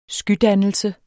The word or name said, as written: skydannelse